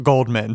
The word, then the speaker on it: Goldman: Simpson not guilty of murdering his ex-wife, Nicole Brown Simpson, and her friend, this is air quotes, Ronald Goldman. Goldman, sorry, I mispronounced your name, R.I.P.